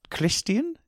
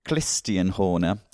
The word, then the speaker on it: Christian: And I thought Christian Horner, Christian? Is that? I've given him a sort of Austrian lilt. – You've given an extra syllable. – Christian Horner.